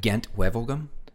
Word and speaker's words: Gent-Wevelgem: Gent-Wevelgem. – Gent-Wevelgem.